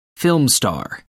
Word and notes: film star: American English

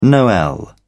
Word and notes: noel: British English

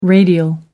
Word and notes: radial: American English

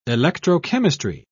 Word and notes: electrochemistry: British English

